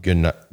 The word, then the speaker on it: Guenat: Dominique Guenat, probably is how you say it, but G-U-E-N-A-T.